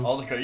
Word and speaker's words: Altucher: So I got it out of a book that I have recently read called Choose Yourself by James Altucher. I might be saying his name wrong. – Aldeca, yeah.